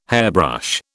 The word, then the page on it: hairbrush: British English